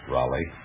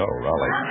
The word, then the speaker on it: Roly: R-O-L-Y. – Oh, Roly. Can you pronounce it Roly? – Yes, sir.